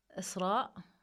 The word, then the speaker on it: Isra: To be honest, I'm still on a journey, but I can say that Isra, this is like how you say my name in Arabic.